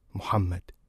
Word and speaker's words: Muhammad: The first time I say it, the way my mother did when she named me, Muhammad, it feels like I have stolen something back.